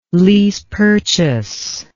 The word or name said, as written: lease-purchase